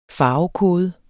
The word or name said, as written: farvekode